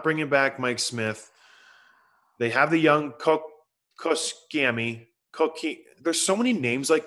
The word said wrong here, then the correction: Koskinen: So it's Koskinen.